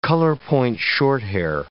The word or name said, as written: colorpoint shorthair